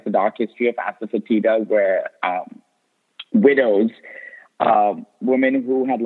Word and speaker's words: asafoetida: What is asafoetida? Did I say it right? – Yes, you did.